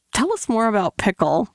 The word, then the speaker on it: PICL: So you've developed a machine learning tool called Pickle, which is spelled P-I-C-L, not like the pickle that you put on a burger.